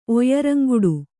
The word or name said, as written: ಒಯ್ಯರಂಗುಡು